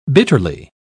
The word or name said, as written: bitterly